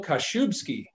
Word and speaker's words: Kaszubski: And today we're joined by Michael Kaszubski, the co-founder of Home Team Mobile Diagnostics.